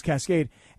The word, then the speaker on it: Kaskade: Like, Cascade is spelled Kaskade, so I just assumed the pronunciation was Cascade.